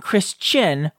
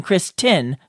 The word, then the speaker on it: Kristen: I swear I almost always hear y'all saying Chris Chin rather than Chris Tin, so I needed to ask. Okay, I can confirm that Kristen's name is pronounced awesome. I'm just kidding. But it is Kristen with a T.